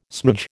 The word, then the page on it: smoodge: American English